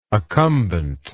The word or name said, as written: accumbent